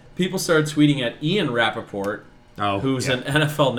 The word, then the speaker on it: Rapaport: I mean, it's Michael Rapaport, or however you pronounce his name. – Yeah, Rapaport.